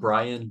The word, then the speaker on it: Brion: So Brian, B-R-I-O-N, Crum.